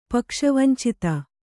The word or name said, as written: ಪಕ್ಷವಂಚಿತ